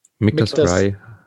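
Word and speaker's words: Michter's: Also beim Michters, die Aussprache, ich habe auch am Anfang gedacht, man spricht das Michters aus. Michael und Peter heißt die Abkürzung.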